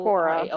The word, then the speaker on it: Quora: I think I'm pronouncing that right. – Yeah. – Quora.